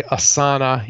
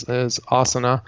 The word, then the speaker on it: Asana: So I have friends who use they say Asana, even though proper pronunciation is Asana, and Trello.